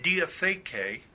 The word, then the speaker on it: diatheke: Now, in the New Covenant era, the Greek word for covenant, diatheke, which would be spelled D-I-A-T-H-E-K-E, that'd be long E's, diatheke, has two meanings.